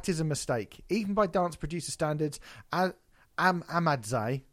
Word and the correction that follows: Ahmadzaï: Ahmadzaï, sorry, I'm always saying his name wrong.